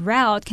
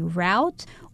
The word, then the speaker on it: route: Now, this word route can be pronounced two ways. You can either say route or route.